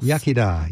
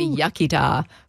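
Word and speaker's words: iechyd da: Yachida. – Yeah, the English say yachida, but it's yachida, good health.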